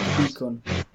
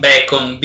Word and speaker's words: beacon: Project Beacon. – Project Beacon. Un bacon, beacon, il bacon è un'altra cosa.